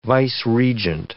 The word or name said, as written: vice-regent